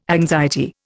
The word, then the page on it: anxiety: Indian